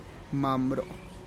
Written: mam'ro

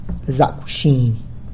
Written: Zac wxiny!

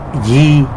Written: ngui

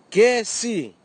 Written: kesi

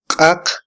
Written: qaq

